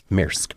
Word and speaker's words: Maersk: Maersk.